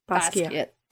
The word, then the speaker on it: Basquiat: Then she started to see Jean-Michel, I'm going to pronounce his surname wrong. – Basquiat.